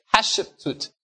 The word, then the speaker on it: Hatshepsut: You pronounce it Hatshepsut.